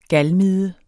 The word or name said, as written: galmide